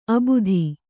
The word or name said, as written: ಅಬುದಿ